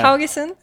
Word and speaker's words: Haugesund: So looking at, and I guess we'll go into, because you did, I'm going to butcher the name, Haugesund. Is that how you say the name? – Yeah. – The one, Haugesund.